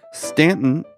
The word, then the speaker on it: Staunton: Stanton is spelled Staunton, by the way. The U is silent.